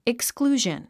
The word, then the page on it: exclusion: iksklúːʒən; イクスクルージョン